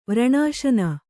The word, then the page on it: ವ್ರಣಾಶನ: Kannada